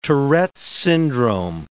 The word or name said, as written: Tourette's syndrome